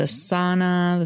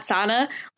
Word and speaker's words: sauna: And then this date was the sauna. The sauna is how I pronounce it. – Sauna? – Yeah. I think that's really that's the Finnish pronunciation.